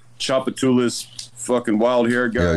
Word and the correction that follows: Giorgio Tsoukalos: Giorgio Tsoukalos.